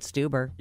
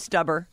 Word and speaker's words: Stuber: Stuber. – Yes. – You'd think it was Stubber.